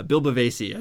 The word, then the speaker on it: Bavasi: Bill Bavasi, I think everyone knew that's how we pronounce his name.